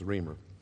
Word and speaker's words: Reimer: Did I pronounce his name right, or is it Reimer? Reimer. R-E-I. He must not be German. But anyway, it's Reimer.